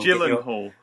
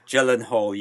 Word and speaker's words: Gyllenhaal: Be careful with that pronunciation. You will Gyllenhaal. – Gyllenhaal. You will get your head snapped off in certain circles if you mispronounce that.